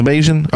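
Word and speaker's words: Bajan: Is that how you say it? – It is. – It's Bayesian? Okay.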